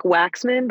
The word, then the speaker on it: Waxman: Uh, it's a little bit more like Waxman, but that Waxman.